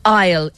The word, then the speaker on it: aisle: Aisle. A-I-S-L-E, aisle. Okay? – As in, I'll poke you in the eye with this pen. – No, as in walking down the aisle.